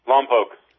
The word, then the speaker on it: Lompoc: Lompoc? How do you say it? – Lompoc. – Lompoc.